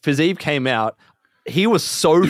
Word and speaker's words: Fiziev: Fiziev. Well, did I say it wrong? – Fiziev.